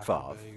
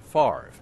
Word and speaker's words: Favre: His family goes back nine generations here, and, yes, Brett Favre, the mayor's cousin, plays football for the Green Bay Packers, where fans tend to mispronounce the name as Favre.